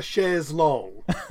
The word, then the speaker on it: chaises longues: But the French plural is S, adding S's on both. – Yeah, but you wouldn't pronounce either S, though. You'd have it. You're at IKEA. At IKEA, you'd go, I want deux chaises longues.